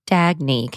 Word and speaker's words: Dagne: Dagny, can you spell that? – Yeah, it's D-A-G-N-E. – Dagny Dover. – Dagny Dover. – Got it. Okay. I wrote Dag Need Over.